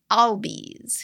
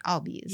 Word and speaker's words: Albies: Albies, who I finally said that right. Albies. – You totally did.